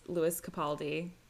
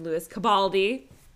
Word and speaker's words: Lewis Capaldi: Um, and yeah, famously not being able to pronounce Lewis Capaldi. She called him Louis Cabaldi.